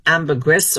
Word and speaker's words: ambergris: And so for people who don't know what ambergris is, it's spelled ambergris, or A-M-B-E-R-G-R-I-S, ambergris, you pronounce it, or ambergris.